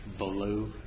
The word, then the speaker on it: Bolough: The second is United States versus, I believe it's pronounced Boulugh, B-O-L-O-U-G-H.